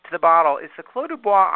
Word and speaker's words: Clos du Bois: By the way, I looked it up in our little wine pronunciation, and you are totally correct. – Oh, that's Clos du Bois. – First. That's a first.